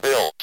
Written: built

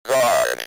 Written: guard

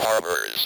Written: harbors

